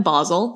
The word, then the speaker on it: Basel: She picked him up in Basel. That's actually how you pronounce it.